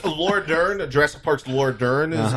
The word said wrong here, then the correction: Laura Dern: Playing, what'd you say? – Jurassic Park's Laura Dern.